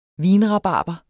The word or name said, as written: vinrabarber